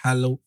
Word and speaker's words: Halle: Halle. – Is that Halle? – Like you say Halle Berry.